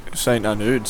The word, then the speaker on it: St. Arnaud: It's all the way to St. Arnaud. Maybe I'm not saying that right. How do you say it? – I think St. Arnaud sounds pretty good, actually.